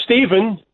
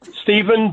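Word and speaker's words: Stephen: You're Steve, but do you spell it S-T-E-P-H? – Yes. It's Steven. Steven, but my...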